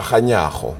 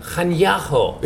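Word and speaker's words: Kganyago: And I've been saying it wrong for years, hasn't I? Lissetia Caniago. But you tell me, go on, you give me the right pronunciation. – Lissetia Caniago. – Caniago.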